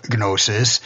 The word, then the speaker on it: gnosis: funny thing about the word gnosis is that in the sound is the word, and I always pronounce it with a G, but in English it's supposed to be silent, I know, unlike right.